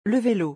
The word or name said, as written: Le velo